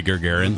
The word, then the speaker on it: Gagarin: How do you say Gagarin? – You were right the first time. – Yeah, Gagarin. Yep.